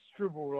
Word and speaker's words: Strubel: Pronounce it Strubel, like apple.